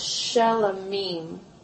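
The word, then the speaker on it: shelamim: So, a regular peace offering, it's pronounced shalamim, or shalom, and what does that sound like? – Shalom. – Shalom, right? Mm-hmm. So that's why it's called a peace offering.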